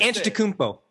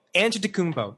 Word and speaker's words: Antetokounmpo: Antetokounmpo. Antetokounmpo. It's not that hard to say. – Antetokounmpo.